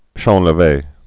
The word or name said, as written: champleve